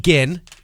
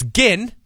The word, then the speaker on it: Ginn: JT Ginn. It's Ginn, not Gin. It's Ginn. Hard G.